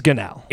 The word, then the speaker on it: Gunnell: This is all projecting that Arizona's quarterback, Grant Gannell, Grant Gunnell, whoever the heck you say his name, it is Gannell, is going to be out next week.